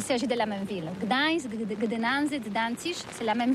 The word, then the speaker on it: Gdańsk: Oui, c'est pas très facile pour des étrangers, mais remarque bien que la langue polonaise, c'est la langue où on prononce chaque lettre qu'on écrit. C'est pourquoi on dit Gdańsk. – Ah, Gdańsk.